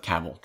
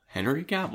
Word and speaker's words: Cavill: I, I think Henry Cable is freaking awesome. – Well, you have Cavill, travel, Cavill. That's how you. Cause I watch things like it's just pronounced travel, Cavill, Henry Cavill.